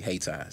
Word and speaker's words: Hayti: Small city, Hayti. Spelled like Haiti, but it's pronounced Hayti.